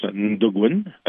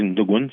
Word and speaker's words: Natogun: Fait que, tout ça pour aussi pour mon album, en fait, qui se prononce Ndogun. Ça s'écrit Natogun, mais on dit Ndogun.